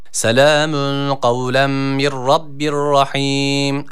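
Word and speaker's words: Kavlem (قولا): Kavlen kelimesine dikkat çekecektik. – Selamun kavlen. – Yani kavlen şeklinde okunuyor. – Selamun kavlem. Kavlem kaf. Yani kef harfi burada yok.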